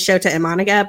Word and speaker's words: Shota Imanaga: I mean, in fairness to you and both of us, like, I have been pronouncing it back and forth all year because I learned it wrong and then I had to learn it right. And so I believe it is Shota Imanaga, but I'm like truly Imanaga.